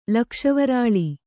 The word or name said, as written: ಲಕ್ಷವರಾಳಿ